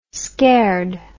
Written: scared

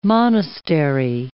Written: monastery

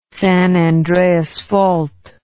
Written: San Andreas Fault

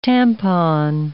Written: tampon

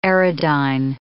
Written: aerodyne

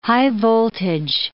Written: high-voltage

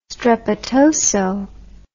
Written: strepitoso

